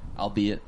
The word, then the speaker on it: albeit: How do you say it? – I'll be it.